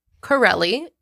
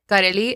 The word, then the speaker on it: Karelly: My name is Crowley in English, Carelli in Spanish. – Mm-hmm. – It's spelled K-A-R-E-L-L-Y.